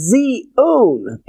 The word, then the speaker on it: Zion: which we pronounce as Zion.